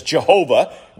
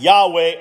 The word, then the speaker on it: Yahweh: years and decades and centuries ago, the name was mispronounced as Jehovah. We still see that name in many of our hymns today. But because of the advancement of understanding of ancient Hebrew and Semitic languages, we think that the earliest members of the Old Covenant Church, they probably pronounced this something like Yahweh or Yahweh. We don't know for sure, but then we know that's probably a lot closer than Jehovah.